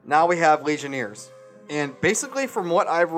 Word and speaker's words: Legionnaires: All right, so 12 cases of Legionnaires.